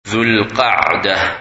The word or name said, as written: Dhul-Qadah